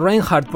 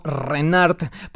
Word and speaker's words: Reinhardt: Por último, terminamos con otro fragmento de este guitarrista Django Reinhardt. Por cierto, su apellido es francés, así que debería pronunciarse algo así como Reinhardt, pero al final se impuso la pronunciación de la lengua inglesa.